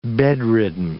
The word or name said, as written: bedridden